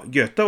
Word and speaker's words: Goethe: Goethe var en stor poet. Så rättar fröken. Nej, nej, lille Pelle. Eh, Oe, det uttalas ö på tyska. Jaha, eh, ja, försök igen då. Ja, Goethe var en stor poet.